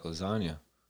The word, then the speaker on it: lasagna: G's moving silence like lasagna. – Lasagna's pretty loud. – The G in lasagna is silent.